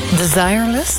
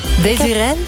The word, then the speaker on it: Desireless: Ja, en de, zeg maar, de klemtoon ligt op de tweede letter. – Maar het is Frans, Frans-Engels is. – Ja, blijkbaar. Ja. En dan de S niet helemaal. Nee. Desireless.